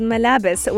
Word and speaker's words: Malabes: It's called Malabes, which is actually clothes in Arabic. It's spelled M-A-L-A-B-E-S.